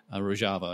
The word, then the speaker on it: Rojava: We also gave air support to the Kurds in northern Syria, what they call Rojava, R-O-J-A-V-A. The J is pronounced like the J in bonjour, it's Rojava.